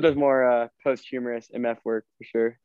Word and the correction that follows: posthumous: No, I don't blame you. I pronounced it like that a long time, and then my mom corrected me. – What is it? – It's posthumous. – Posthumous. – Posthumous. – Like after death?